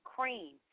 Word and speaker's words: Qream: Pharrell Williams has created a silky drink along with the makers of Ciroc called Cream. It's spelled Q-R-E-A-M, cream.